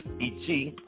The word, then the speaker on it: ichi: We're starting off with 1, which is Ichi, I-C-H-I, Ichi.